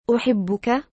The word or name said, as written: uhibbuka